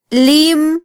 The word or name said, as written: lim